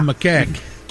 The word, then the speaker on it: macaque: Is that how you say it? – Yeah. And how did you say it? – Well, I always told you it sounds like how a Boston guy would refer to his penis. – Right. – Macaw. A macaque.